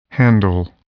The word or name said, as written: handle